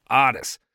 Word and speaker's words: Otis: Yeah, I'm not mispronouncing that. It's not Otis, okay? Chill. It's Otis. Collecting food stamps, they were, in Florida.